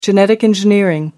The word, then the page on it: genetic engineering: American English